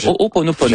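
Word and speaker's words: O'oponopono (Hoʻoponopono): Non, c'est une philosophie hawaïenne de, je vais le prononcer comme il faut, de l'O'oponopono. Le O'oponopono.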